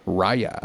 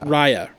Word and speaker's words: Raya: Raya. – Raya. Raya and the Last Dragon. That's R-A-Y-A.